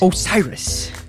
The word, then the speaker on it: Osiris: Might I mention, I'm glad you said Osiris because I wasn't sure how to pronounce it. – Osiris.